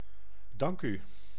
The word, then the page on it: thanks: /ˈθæŋks/